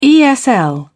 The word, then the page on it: esl: British English